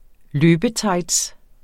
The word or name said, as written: løbetights